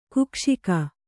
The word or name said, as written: ಕುಕ್ಷಿಕ